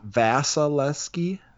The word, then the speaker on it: Vasilevskiy: Vasilevskiy. – Okay. Well, I'm going to go with Vasilevskiy.